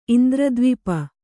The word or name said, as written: ಇಂದ್ರದ್ವಿಪ